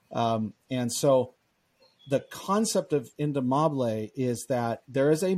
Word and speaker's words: indomable: It's a Spanish word. It's indomable is how you say indomable.